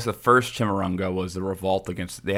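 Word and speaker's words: Chimurenga: But he would call this era the third Chimarrunga. I think that's how it's pronounced. – Chimichanga. – And Chimichanga. I think it's Chimarrunga. – It's Chimarrunga. I'm just fucking around.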